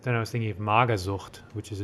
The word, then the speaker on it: Magersucht: Magersucht. – Sucht. Sucht. Spell it. – Mager, M A G E R, and Sucht, S U C H T. Magersucht.